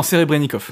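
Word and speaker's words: Serebrennikov: donc un film russe de Sobrenikov, je sais pas si je le dis bien. Ouais, Kirill non, Serebrenikov. Serebrenikov, hein, qui est un ennemi de Poutine, d'ailleurs, on peut le dire.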